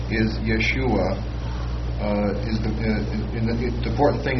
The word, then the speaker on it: Yeshua: And this one, according to the analytical Greek lexicon, the first one, pronounce it again. – Yeshua.